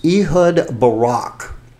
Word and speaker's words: Ehud Barak: And if I get the pronunciation wrong, Terry, maybe you remember this guy because I really don't. This is Ehud Barak. He is the former... – Yep, you're close enough, yeah.